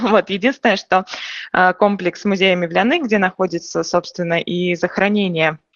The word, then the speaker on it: Мевляны: Мы говорили уже о мечети Мавзолея Мевляны, правильное ударение, да? – Мевляны, скорее.